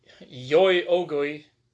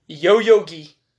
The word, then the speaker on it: Yoyogi: And in July of that year, the Square Enix headquarters were moved to Yoyogi. – That sounded butchered. – Yoyogi. Yeah. Good lord. Yoyogi Shibuya, Tokyo. Yeah, it's Yoyogi.